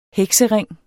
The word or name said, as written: heksering